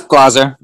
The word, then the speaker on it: Glaser: That's Glaser.